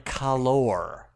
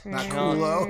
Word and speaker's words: calor: It is pronounced Mango Magnifico Con Calor. Not culo. Not ass.